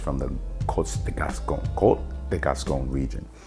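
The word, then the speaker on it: Côtes de Gascogne: And it's from the Côte de Gascogne region, Côte de Gascogne. It's spelled C-O-T-E-S, de Gascogne.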